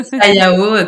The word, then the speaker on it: sayaw: Sayao, donc ça s'écrit S-A-Y-A-W.